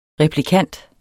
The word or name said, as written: replikant